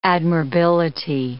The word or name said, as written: admirability